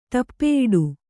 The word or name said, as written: ಟಪ್ಪೆಯಿಡು